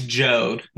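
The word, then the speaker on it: Jeod: It's Jode, like rhymes with bode.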